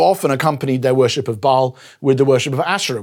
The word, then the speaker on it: Baal: particularly one around a god called Baal, or sometimes pronounced Baal, B-A-A-L, and they've been worshipping him.